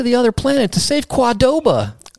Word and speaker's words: Qdoba: I said, how do you actually pronounce the name of this place? Because I've heard Qdoba and Kadoba. And she was like, oh, you're not going to like this. And I was like, what? She goes, it's actually properly pronounced Quadoba.